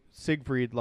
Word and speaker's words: Siegfried: Well, to the older people or, like, our generation-ish, I say Siegfried like Siegfried and Roy.